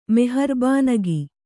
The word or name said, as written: ಮೆಹರ್ಬಾನಗಿ